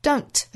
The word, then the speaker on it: don't: 前面半句呢有两处 t 的爽读。 Don't 尾部的 t 音。 Just 尾部的 t 的发音。为了句子读起来更加流畅。把前面的 t 的音都省略掉。